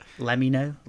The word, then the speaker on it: Lemino: No, it's like, it's pronounced Lemino, like let me know, like play on words kind of thing.